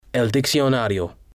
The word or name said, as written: el diccionario